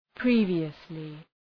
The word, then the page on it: previously: ‘pri:vıəslı